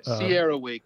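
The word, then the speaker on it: CERAWeek: Yeah, Sierra Week. So it's an acronym. We love our acronyms in chemicals. Yeah. This is for Cambridge Energy Research Associates.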